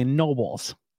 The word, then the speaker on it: Knoebels: Then the pronunciation is Knoebel. – Knoebel. – So there you go. Because some people say Knoebels. I'm going to Knoebels. – Knoebels. – It's that silent K.